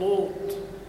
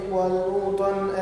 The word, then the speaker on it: لوط: ونجيناه ولوطا ذكرت قبل ذلك. قلنا حرف اللام من كلمة لوط ينطق مفخما وهذا لحن، فيقول لوط، هذه مشكلة. ولوطا إلى ولو مر نفسك بارك الله فيك.